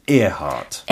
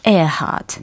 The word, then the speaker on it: Earhart: 어떻게 발음하신다고요? – Earhart. Earhart.